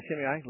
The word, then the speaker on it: Shimei: and he tells Solomon not to let Shimei, am I saying that right? – Uh-huh, yeah, Shimei, let him not come to a peaceful grave.